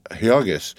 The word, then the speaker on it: Jurgis: She was so young, not quite 16, and small for her age, a mere child, and she had just married and married to Jurgis, pronounced Jurgis of all men,